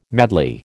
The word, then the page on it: madly: American English